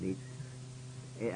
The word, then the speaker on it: Ypres: Yes, immediately behind Ypres. – Ypres. – Well, the troops called it Wipers, but it was spelled Y-P-E-R-S or something or other, and we called it Ypres.